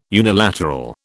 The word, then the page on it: unilateral: British English